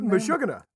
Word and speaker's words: Meshuggah: How do you say that band? – Meshuggah. – Oh, it is really Meshuggah.